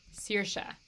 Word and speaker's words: Saoirse: Sarsa Ronan, or is it Shersa? Sarsa? I think it's uh, Saoirse. – Saoirse. – Saoirse Ronan. – Saoirse yeah. Ronan, yeah.